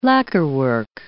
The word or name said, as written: lacquerwork